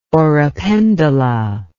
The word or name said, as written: oropendola